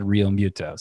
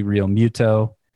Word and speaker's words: Realmuto: I got to train myself to say it differently because I heard him say it himself and he said Realmuto. So despite some of the pronunciation guides online, going straight from the horse's mouth on that, JT Realmuto.